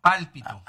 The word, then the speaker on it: pálpito: Yo tengo ese palpito que se siente. ¿Cómo se llama? No, lo dije mal. Perdón. El pálpito.